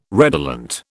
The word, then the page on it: redolent: British English